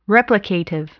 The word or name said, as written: replicative